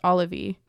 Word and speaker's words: Olivie: I think her name's pronounced Olivie, kind of like Olivia, but with an E.